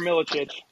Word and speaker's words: Milicic: On top of that is the Milicic, I believe that's how you say it. – Yeah, Igor Milicic.